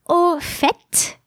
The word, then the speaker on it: au fait: You may have noticed that in two of these expressions, I sounded the final T and not in the third one. Au fait, where you do pronounce the final T, means by the way.